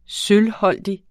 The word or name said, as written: sølvholdig